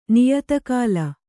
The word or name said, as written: ನಾಯಿತಕ್ಕಿಲೆ